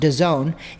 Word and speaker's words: DAZN: It's DAZN, but it's pronounced DAZN.